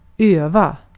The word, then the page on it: öva: /ˈøːva/